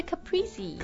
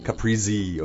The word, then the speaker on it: Caprese: It's the Caprese. – Caprese, okay. – Caprese, 就是是应该是嗯可能意大利 Capri 小岛、源自这个地方的沙拉。一个 Caprese